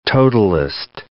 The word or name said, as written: totalist